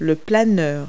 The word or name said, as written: glider